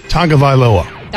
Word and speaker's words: Tagovailoa: Clemson quarterback Trevor Lawrence named first-team All-American QB over Alabama quarterback Tua Tagovailoa, which I'm pretty sure I said wrong, so feel free to repronounce that for me because I'm never going to get it right. – Tagovailoa.